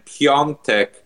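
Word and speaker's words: Piątek: Then we had Genoa striker Piatek, who I learned you actually pronounce his name Piontek.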